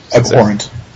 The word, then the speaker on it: abhorrent: Abhorrent. You would do pronounce the H.